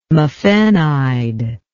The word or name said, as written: mafenide